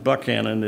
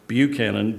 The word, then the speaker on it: Buchanan: And it's not pronounced Buckcannon, it's Buchanan.